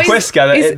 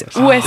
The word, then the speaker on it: Huesca: You said Huesca. The H is silent. – Yeah, it's Huesca.